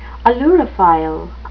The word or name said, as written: ailurophile